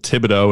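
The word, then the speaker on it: Thibodaux: It's called Thibodeau, and it's spelt with it's got an X in there. Like, it finishes with the letter X.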